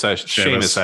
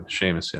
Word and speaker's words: Seamus: It's Seamus, actually. Seamus, yeah.